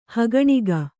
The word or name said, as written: ಹೇಕಣಿಕೆ